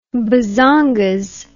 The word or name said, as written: bazongas